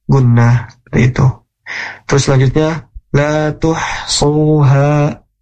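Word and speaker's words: lā tuḥṣūhā: Jadi ketika mengucapkan huruf h maka biasa aja gitu ya dalam pengucapannya. La tuhsuha, suha itu enggak la tuhsuha ha, terlalu takaluf gitu ya, terlalu berlebihan dalam mengucapkan huruf ha seperti itu.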